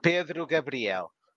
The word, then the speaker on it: Pedro Gabriel: Do you want to pronounce it in Portuguese just so we can? – Pedro Gabriel. – Yeah, I can't. Exactly. – It's not Spanish.